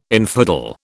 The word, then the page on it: infidel: British English